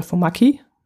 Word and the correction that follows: Formaggi: so spricht man es glaube aus. Nee, Formaggi. Formaggi müsste es heißen.